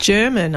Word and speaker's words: German: The men who menace them are Chuck, played by actor Aaron Glenane, and German, I think it's pronounced. – German. – Just German. There you go.